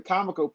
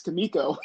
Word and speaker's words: Comico: What, Comico? Shuriken. – Actually, it was the Comico part. It's Comico. – I always do that. But, man. – Yeah, everybody pronounces it Comico.